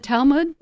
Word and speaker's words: Talmud: In the Talmud, is that how you say it? – Yes.